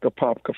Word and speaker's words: Apopka: Let's go now in, I always mess up the Florida names, Apopka. Am I saying that right? Apopka, Florida? – That is correct. Apopka, Florida.